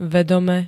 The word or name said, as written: vedome